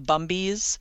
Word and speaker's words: Bumbies: And then they had this couple I'd never heard of before called the Bumbies, rhymes with Gumby.